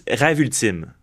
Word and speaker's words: Rêve Ultime: Did they say it right? – Yeah, it was Rêve Ultime.